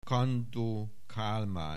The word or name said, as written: Kandó Kálmán